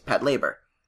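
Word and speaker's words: Patlabor: He actually directed the, Pet Labor. It's actually, I've been watching it, since it's pronounced. So no one can douchebag me. It's Pat Labor. – Pat Labor. – Since the robots are called Labors.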